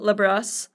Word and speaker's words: Labrosse: So today we have our good friend and occasional voiceover expert, Joel Labrosse. Like lacrosse, yes? – Yes, the correct pronunciation would be Labrosse. It's French, but I don't expect the general public to do that on a day-to-day basis.